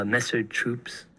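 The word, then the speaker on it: Messer Chups: So up next is a more recent Russian group, Messer Troops. I don't know if I said that right. – Yeah, you're right.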